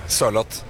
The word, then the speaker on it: Sørloth: Sørloth.